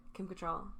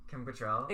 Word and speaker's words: Kim Cattrall: Kim Cattrall. Kim Cattrall.